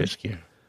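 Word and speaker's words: Ceaușescu: It was the guy was Kuchescu, is that right? Is that how you say his name, the leader back then? – Ceausescu. – Ceausescu, yeah.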